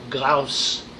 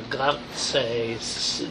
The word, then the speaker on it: Glauce: And she's taken as her squire a Glaus, or Glauce, G-L-A-U-C-E, Glauce, Glauce, Glauch. You could probably see how to spell it if he rhymed it anywhere.